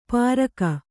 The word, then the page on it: ಪಾರಕ: pāraka